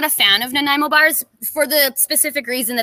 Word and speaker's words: Nanaimo: Now, what we don't have in the U.S., as far as I know, is Nanaimo bars. Am I saying that right? – Yeah, Nanaimo.